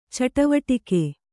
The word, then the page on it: ಚಟುವಟಿಕೆ: caṭuvaṭike